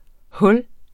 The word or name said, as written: hul